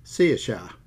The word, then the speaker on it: Saoirse: Now, before I do that, some famous personality just named their daughter CSHA, which is a nice name, but it's spelt S-A-O-I-R-S-E, CSHA.